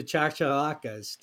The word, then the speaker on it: chachalacas: There will be a special episode of On the Wing devoted exclusively to chachalacas.